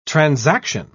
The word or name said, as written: transaction